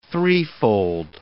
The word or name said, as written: threefold